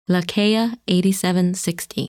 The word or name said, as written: Lacaille 8760